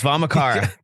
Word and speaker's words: Vamachara: Well, it's Vamachara. We say Vamachara.